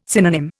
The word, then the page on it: synonym: Indian